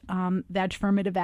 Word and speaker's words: vagformative: Could you talk about vagformative action and why it's so important? – Yes. – And did I say it right? – Yes, you did.